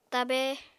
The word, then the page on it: tabe: Apatani